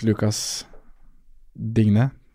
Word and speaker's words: Lucas Digne: Uh, jeg syns Lucas Digne Mm.